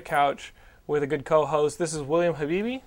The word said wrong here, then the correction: Bibbiani: How is it? – Bibbiani. – Bibbiani? – Yes.